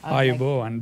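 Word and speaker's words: Ayubowan (ආයුබෝවන්): Ayubowan, this is how you say it.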